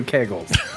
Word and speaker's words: kegel: I'm pretty sure I've been saying all along that men can do kegels. – you were early on that. – Kegels? Yeah. Wait, do you bagel kegels? – You just pronounce kegels. That's how you pronounce it, kegel.